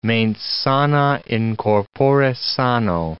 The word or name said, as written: mens sana in corpore sano